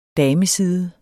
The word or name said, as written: dameside